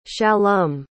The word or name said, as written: shallum